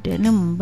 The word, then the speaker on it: Denim: Denim, benar bacanya begitu.